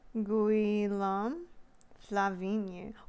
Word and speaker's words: Guillaume Flavigny: Guillaume Flavigny. Wow, Flavigny just sounds like flavonoid.